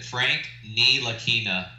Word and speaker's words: Ntilikina: Frank Ntilikina.